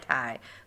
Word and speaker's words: Tai: And I didn't know how to pronounce Kai and Tai. So I literally wrote Kai, KY, and Tai, like the Thai food.